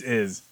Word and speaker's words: Ys: It rhymes with geese, your favorite KOF character. – What? It's called YS, right? – Yeah, it's YS, the Chronicles of YS. – Okay, it's Iz. Got it.